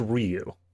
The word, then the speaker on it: Ryu: It's pronounced Ryu.